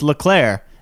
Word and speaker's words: Leclerc: There's four silent letters. There's a lot of silent letters. Point is, literally on the way to Quebec, you take a Félix Leclerc highway. And LeClaire is like Smith in Ontario.